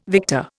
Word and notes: victor: Indian

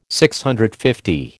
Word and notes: DCL: American English

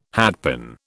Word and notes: hatpin: British English

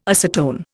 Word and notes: acetone: Indian

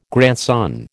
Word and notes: grandson: American English

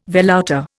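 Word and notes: velouté: Indian

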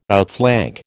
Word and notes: outflank: American English

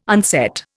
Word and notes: unset: Indian

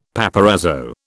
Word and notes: paparazzo: British English

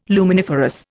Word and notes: luminiferous: Indian

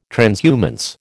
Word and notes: transhumance: American English

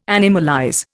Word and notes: animalize: Indian

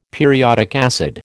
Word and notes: periodic acid: American English

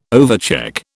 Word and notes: overcheck: British English

